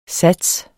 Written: sats

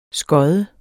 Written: skod

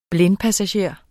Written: blindpassager

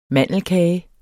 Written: mandelkage

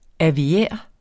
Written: aviær

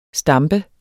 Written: stampe

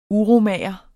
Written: uromager

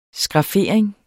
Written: skraffering

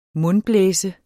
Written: mundblæse

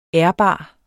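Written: ÆRBART

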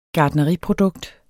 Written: gartneriprodukt

